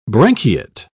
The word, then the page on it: branchiate: British English